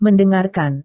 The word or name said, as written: mendengarkan